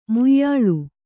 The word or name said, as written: ಮುಯ್ಯಾಳ್